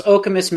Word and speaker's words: Okemos: You were so close. Okemos, Michigan is the name of the town. You got it on the second try.